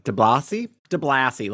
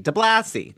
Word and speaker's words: DeBlasi: I may be butchering that, but DeBlasi, like Degrassi, DeBlasi.